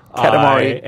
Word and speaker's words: Katamari: Calamari? – I'm not even lying. You are saying it wrong. – Said it too quickly? – Katamari.